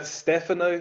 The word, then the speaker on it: Stefano: It's Stefano. Emphasis on the first E.